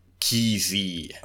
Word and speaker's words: Kesey: and it looks to be pronounced Kesey.